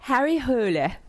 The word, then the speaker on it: Harry Hole: Harry Hole. I know. Emma, did I say it right? – Yeah.